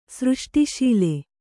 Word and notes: ಸೃಷ್ಟಿಶೀಲ: kannada

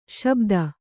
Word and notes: ಶಾಬ್ದ: śabda

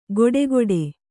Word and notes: ಗೊಡೆಗೊಡೆ: Kannada; goḍegoḍe

